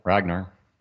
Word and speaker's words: Ragnar: Ragnar.